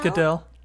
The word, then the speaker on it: Goodell: Is it pronounced Goodell? Goodell? – Goodell.